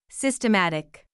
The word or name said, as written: systematic